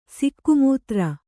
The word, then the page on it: ಸಿಕ್ಕುಮೂತ್ರ: sikku mūtra